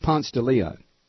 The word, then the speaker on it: Ponce de Leon: But it's pronounced here. If you said, can you tell me where Ponce de Leon Avenue is? People would not pay attention. Here it's pronounced Ponce de Leon.